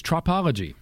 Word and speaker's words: tropology: Trophology is the word of the day. Anybody got it? – It's correct pronunciation. In fact, it's tropology.